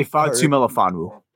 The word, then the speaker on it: Ifeatu Melifonwu: Ifeatu Melifonwu. – Ifeatu Melifonwu.